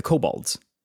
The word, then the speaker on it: Kobolds: You do represent the kobolds. How do you say their name? – Kobolds. – Kobolds. I did it right that time.